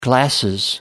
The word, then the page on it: glasses: British English